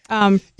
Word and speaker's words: prosthesis: So part of the reason behind this, was it a difficult process for you to get your prosthesis? – It was. – I'm saying that wrong, aren't I? Prosthesis? – You know, you did that. That's correct. Yeah.